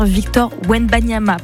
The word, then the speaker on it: Victor Wembanyama: Il s'appelle bien ce basketteur Victor Wembanyama, pardon pour l'erreur de prononciation.